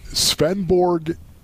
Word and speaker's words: Svendborg: Okay, so, like, speaking of that, Yelda, right there, the Dane, you mentioned it, Svendborg, Denmark. Did I say that correctly? – Yes, sir. That was good.